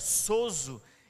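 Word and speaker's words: sozo (σώζω): Salvação ali significa uma palavra no hebraico que diz que, é que se, se pronuncia sozo.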